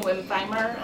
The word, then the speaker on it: Wimbfheimer: Eden Wimfheimer, I'm the first select woman of the town of East Granby. W-I-M-B-F-H-E-I-M-E-R.